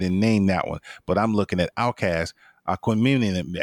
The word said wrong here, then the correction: Aquemini: I never can pronounce that right. But their second album came out, and that album was very strong. It did very well. – It's Aquemini. – Aquemini, thank you.